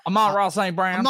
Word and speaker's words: Amon-Ra St. Brown: How do you say that, boy? – Amon-Ra St. Brown.